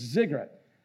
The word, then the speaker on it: ziggurat: I actually put it on Google Translate last night, and it spells like, when it's spelled out, it's like ziggurat, but it's ziggurat.